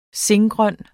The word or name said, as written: singrøn